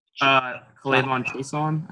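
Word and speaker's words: K'Lavon Chaisson: Kalavion Chaisson.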